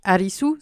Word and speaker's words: Arisu: Euh, petite euh, fun fact, Arisu, c'est la prononciation japonaise de Alice.